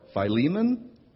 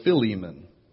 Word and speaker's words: Philemon: So apparently the acceptable pronunciations of the book are Philemon or Philemon. Both are acceptable.